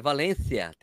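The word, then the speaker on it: Valencia: Tá falando errado, é Valência, tem que ter outro. Valência.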